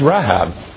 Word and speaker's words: Rahab: And while our English inclines us to pronounce the names the same in verse 7, this isn't the Rechab who was the hostess of Jericho for Joshua and the spies, commended for her faith in the book of James, but rather this, by its Hebrew pronunciation, is Rahab, a storm,